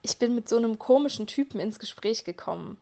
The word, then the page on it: ins Gespräch kommen: German